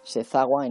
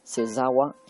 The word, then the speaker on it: Sezawa: Se escribe con Z. Sezawa en español, Sezawa en japonés.